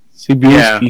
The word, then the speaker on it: Cebulski: Cebulski, how do you say his last name? Cebulski? – Yeah, that's what I say. Cebulski.